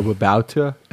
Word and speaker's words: Babauta: I was listening to your incredible conversation with Leo Babauta.